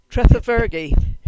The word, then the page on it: Tredhevergi: Cornish